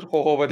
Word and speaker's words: Jojoba: Jojoba diye yazılır, Hohoba diye okunur.